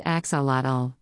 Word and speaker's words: axolotl: If you've ever looked at the word axolotl and wondered what it denotes and how to say it, you're not alone. Pronounced axolotl, this amphibian looks like a curious mix of salamander and fish.